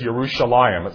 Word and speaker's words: Yerushalayim: In fact, when I took Hebrew in seminary, the modern names of Israelite locations like Jerusalem in Hebrew is Yerushalayim.